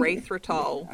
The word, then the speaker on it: erythritol: How do you say that other one? – Erythritol. – I always say erythrosol, and I know that's not right.